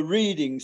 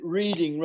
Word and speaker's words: Reading: There's one thing where Proby loses it or gets it wrong because he's just reading, and he gets Reading, the town, for Reading. So he pronounces it Reading rather than Reading.